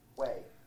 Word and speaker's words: Hue: Were you 101st Airborne Division in Hue? – Way. – Way, excuse me. It's spelled H-U-E.